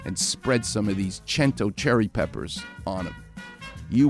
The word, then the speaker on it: Cento: And the company is called Cento, C-E-N-T-O. It's C-E-N, pronounced CH, because it's Italian.